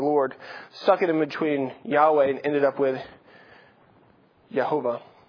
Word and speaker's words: Yahweh: As a general rule, pretty much everybody says, okay, Jehovah, yeah, that's not it. It is Yahweh, or something very close to the pronunciation, Yahweh.